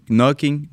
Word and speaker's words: Knocking: Knocking on ne prononce pas le N normalement.